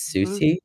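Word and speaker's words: Siouxsie: I pronounced this band wrong earlier, so correct me again. It's Susie.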